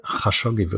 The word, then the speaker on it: Khashoggi: für nicht nur Khashoggi, Khashoggi wird da ausgesprochen, weiß ich inzwischen, also nicht nur für das, sondern sondern für den Background äh, von MBS.